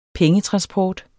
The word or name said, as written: pengetransport